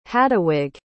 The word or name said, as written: haduwig